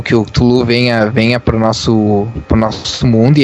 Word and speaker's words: Cthulhu: Na verdade, a pronúncia seria Cthulhu.